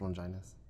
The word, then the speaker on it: Longinus: Pronounced Longinus. – Longinus. – Just letting you know, that's how Hellboy pronounces it, so it's pronounced that way.